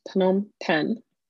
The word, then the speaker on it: Phnom Penh: And its capital, which is spelt P-H-N-O-M-P-E-N-H, two words, is pronounced Phnom Penh, according to multiple internet sites.